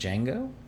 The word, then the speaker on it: Django: J-A-I-M-I-N, with the D being silent. – Oh wow. – So it's like Django? – The Django. – The D is silent.